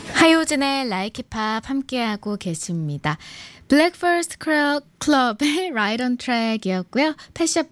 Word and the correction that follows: Breakfast Club: Breakfast Club. 발음이 왜 이렇게 꼬이죠?